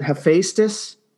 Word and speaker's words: Hephaestus: And first off, how do we pronounce the name of this? Is it Hephaestus? – Yeah, that's what we always say.